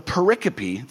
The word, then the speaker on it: pericope: It looks like pericope, P-E-R-I-C-O-P-E. But it's pronounced pericope.